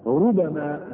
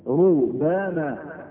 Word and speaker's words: ربما: تقرأ الآية عليك ربما، مش نطقة كده، ربما بالتخفيف ربما، سمعت حد يقرأها بالتشديد ربما؟ سمعت؟ لكن هو في قراءة، القراءة قراءة حفص حفص المشهورة عندنا ربما بالتخفيف كده، التخفيف يعني مفيش ايه؟